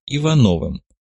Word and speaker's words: Ивановым: Кстати, представлюсь, моя партийная кличка Агосфер, но сейчас меня чаще называют Ивановым. Ударение на втором слоге по-офицерски.